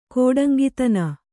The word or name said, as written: ಕೋಡಂಗಿತನ